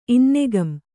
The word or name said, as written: ಇನ್ನೆಗಂ